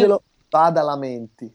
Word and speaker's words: Angelo Badalamenti: Angelo Badalamenti.